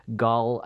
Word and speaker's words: Gal: And I was thinking it was a silent T, but she's not French; she's Israeli. So it is actually Gal, as in ball, I guess, and Gadot with the pronouncement of the T at the end.